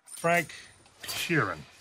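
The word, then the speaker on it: Frank Sheeran: פרנק שירן, I saying that right? Yeah, you said right.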